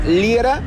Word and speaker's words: Liera: Mándame un mensajito, Fernando Liera, L-I-E-R-A, así se escribe mi apellido, Liera, L-I-E-R-A, y dime dónde quieres que esté la ruta del amor.